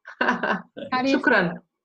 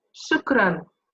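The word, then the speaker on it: Shukran (شكرا): How do you say thank you in Jordan? – In Arabic? It's in Arabic. Shukran. – Huh? – Shukran.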